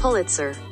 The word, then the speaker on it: Pulitzer: Once again, Kendrick was nominated for eight Grammys and was also awarded the Pulitzer Prize. Sorry, I don't know how to pronounce that. Wait, hold on. Let's ask Google. Pulitzer. So apparently that's how it's pronounced.